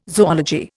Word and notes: zoology: Indian

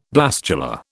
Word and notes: blastula: British English

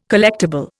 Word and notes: collectible: Indian

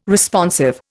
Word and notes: Responsive: Indian

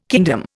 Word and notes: kingdom: Indian

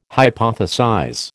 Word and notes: hypothesize: American English

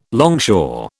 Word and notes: longshore: British English

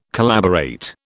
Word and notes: collaborate: British English

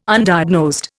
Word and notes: Undiagnosed: Indian

